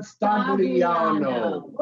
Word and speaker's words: Stagliano: That's why he's not calling, because they're not pronouncing his name right. It's John Stagliano.